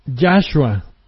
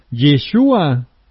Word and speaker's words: Yeshua: Ahora, otra cadena de engaño, que se debe de pronunciar Yahshua. Y si usted va a una enciclopedia e investiga el nombre correcto y la pronunciación en hebreo, se va a dar cuenta que es Yeshua, no Yahshua.